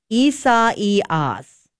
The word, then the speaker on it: Isaias: Hey, I pulled up the pronunciation for this hurricane. Isaias. – Isaias. – Isaias. – And do you emphasize the second E? Isaias. – Isaias. – I think that's right.